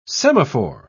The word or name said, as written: semaphore